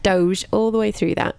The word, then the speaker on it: Doge: We're pronouncing it Doge.